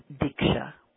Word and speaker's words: diksha: The word for Kundalini awakening or for Kundalini transmission is diksha. It's a Sanskrit word which has two syllables with two meanings. Di means it's associated with giving, and ksha, which is associated with cutting.